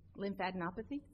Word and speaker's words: lymphadenopathy: Now, Dr. Corby in his report states that Yates developed chronic, and I'm going to butcher the word, lymphadenopathy? – It's easy for you to say, yes.